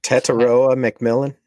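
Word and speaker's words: Tetairoa McMillan: Tetaroa McMillan. – Exactly what I was going to say.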